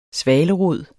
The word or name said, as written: svalerod